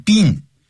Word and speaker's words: been: Have you been, B-E-E-N, 加个 to. 那个 been, 好多人念成 been, 也念成 been, 短音节 it, 短的元音 been, 这样说。